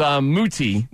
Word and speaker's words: Muti: And it was Muti. That's how you're supposed to say it. That's what the pronunciation guy says.